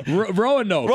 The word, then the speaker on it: Roanoke: Roanoke.